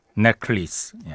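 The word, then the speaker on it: Necklace: 앞에 들으신 음악은 라나델 레이, 피처링 존 바티스트, 캔디 넥클리스. 예. 이게 넥하고 레이스가 따로 있을 때는 이렇게 발음되는데 합쳐져서 목걸이가 되면 넥클리스 이렇게 발음이 되는군요.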